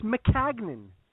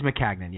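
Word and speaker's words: Maccagnan: He clarified the pronunciation. It's McCagnan. – It is McCagnan, yes. – Yes, we were right. – We were right. – I was trying to make it McCagnan because I had seen that originally phonetically spelled out by one of the beat reporters. It's McCagnan. – And he had a good line where he said, just break it down into three syllables.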